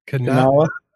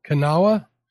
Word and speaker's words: Kanawha: She says, a tip for first time chasing flathead in the, how do you say that? – Kanawha. – Kanawha.